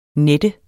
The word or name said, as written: nette